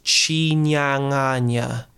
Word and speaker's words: Chinyanganya: And then Chinyanganya is actually pronounced, so in Shona, that language, N-Y-A is nya, and N-G is ng. So it's Chinyanganya.